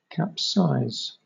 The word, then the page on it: capsize: British English